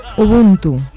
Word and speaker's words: Ubuntu: Ubuntu. – Ah, he dicho Ubuntu.